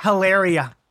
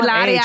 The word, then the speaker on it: Hilaria: The way you said it, though, you said it properly, not Hilaria. You know, Hilaria. The fact you said the silent Ilaria. – H.